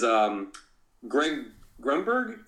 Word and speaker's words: Greg Grunberg: And you should see the last story is Greg Grunberg.